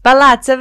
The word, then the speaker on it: Palazzo: Veru Pali would be rocking Palazzi Versace. Did I say it right? – Palazzo? – Palazzo Versace.